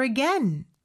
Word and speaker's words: again: again 발음은요. again이 아닙니다. again 이렇게 에 발음으로 해주셔야 돼요.